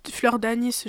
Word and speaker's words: fleur d'anis: Fleur d'anus. – Tu dis. J'en mets pas. Du axe. – Tu dis fleur d'anis, genre une petite syllabe.